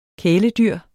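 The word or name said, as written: KÆLEDYR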